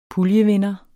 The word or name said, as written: puljevinder